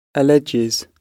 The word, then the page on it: alleges: British English